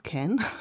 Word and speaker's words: Can: Die in Köln ja englisch ausgesprochen wird, Can, lustigerweise, ja.